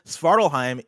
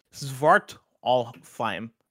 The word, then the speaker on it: Svartalfheim: Kebabs writes in and says, Svartalheim in AC Valhalla's Dawn of Ragnarok DLC is pronounced Svartalfheim.